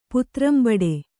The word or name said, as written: ಪಾತ್ರಂಬಡೆ